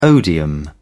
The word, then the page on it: odium: British English